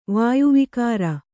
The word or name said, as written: ವಾಯುವಿಕಾರ